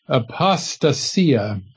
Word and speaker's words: apostasia: Now, as I pointed out last time, that word translated falling away there, if we pronounce the Greek word, this is the Greek word, apostasia.